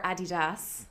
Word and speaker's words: Adidas: you mentioned Adidas um, or Adidas. It's a German company, and that's how they say it.